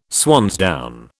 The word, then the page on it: swansdown: British English